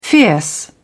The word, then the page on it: fierce: British English